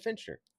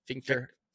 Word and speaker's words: Fichtner: Is this pronounced Fichtner? I've always said Fincher. – Fincher. – I thought it was Fichtner. – Fichtner. – Fichtner. Okay, Fichtner.